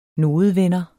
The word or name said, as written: nodevender